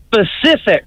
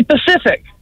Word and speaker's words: specific: I would say one of my biggest words is specific. – Yes, specific. What's wrong with that? – People say Pacific.